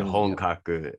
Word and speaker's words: Honkaku: It is not the easiest Japanese word that we could have chosen as the name of the company, but it's pronounced Honkaku.